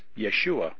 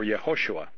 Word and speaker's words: Yeshua: Okay, his name was Yeshua or Yehoshua. Okay, just like Joshua in the Old Testament, same name. It's just different ways of pronouncing it. They different contractions. The actual name was Yehoshua, and it could be contracted to Yeshua or Joshua.